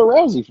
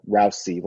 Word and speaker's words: Rousey: Brian, I like how you pronounce Rousey, like Rousey, like a mouse. Rousey.